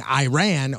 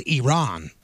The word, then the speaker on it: Iran: Then why have you continuously gone about mispronouncing our country's name by saying Iran when it is Iran? – And then he also said...